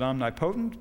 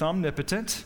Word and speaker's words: omnipotent: Some pronounce it omnipotent, but it's omnipotent.